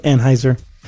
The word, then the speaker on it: Anhyzer: It's spelled A-N-H-Y-Z-E-R, but I'm sure it's pronounced Anheuser.